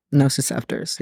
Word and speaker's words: nociceptors: Nociceptors. Did I say that right? – Nociceptors, yes.